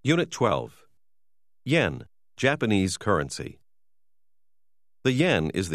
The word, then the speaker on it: yen: dollar is now worth about 120 to 140 yen. Although in writing it is symbolized as yen, its pronunciation is more like N. – There are four notes: 10,000 yen, 5,000 yen, 2,000 yen, and 1,000 yen that are of different sizes and colors.